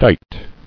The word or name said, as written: Dight